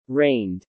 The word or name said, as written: reigned